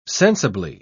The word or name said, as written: sensibly